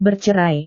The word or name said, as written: bercerai